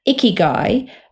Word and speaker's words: ikigai: ikigai,